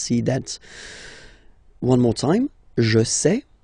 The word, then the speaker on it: sais: Je sais. Okay, final S not pronounced, and then you get this A I E.